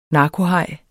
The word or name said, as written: narkohaj